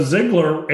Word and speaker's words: Ziglar: It's a German name, and it's generally spelled differently than what we spell it. It's usually, we spell it Ziglar, and almost always it's ER, and sometimes there's an I in it, so it'll be like Ziegler, like Ziegler.